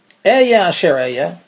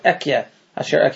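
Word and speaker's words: Eya asher eya (אהיה אשר אהיה): And Hashem said to Moshe, Eya asher eya. Some pronounce that ekya asher ekya because it's an argument whether that's a name of God or it's just the phrase, I will be as I will be.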